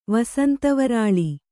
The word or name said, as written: ವಸಂತವರಾಳಿ